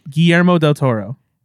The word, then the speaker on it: Guillermo del Toro: How do you want me to say his name? – Guillermo del Toro. – All right. – Guillermo, so as gringo as you can.